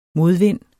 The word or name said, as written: modvind